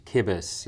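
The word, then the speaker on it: Cibus: Did you say kibbis? – Kibbis, yeah. So it's actually Latin, and kibbis means food.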